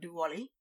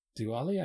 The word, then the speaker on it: Diwali: And what about Diwali? – Diwali, I think, is mostly the same season every year.